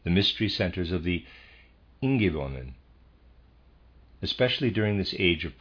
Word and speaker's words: Ingäwonen: Readers aside, Ingäwonen, excuse me, Ingäwonen, I'm going to spell that, it's I N G A umlaut W O N E N, Ingäwonen.